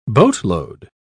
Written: boatload

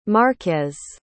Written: marquez

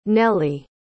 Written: nelie

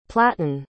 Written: platon

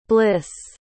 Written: blisse

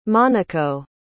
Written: monaco

